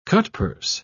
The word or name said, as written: cutpurse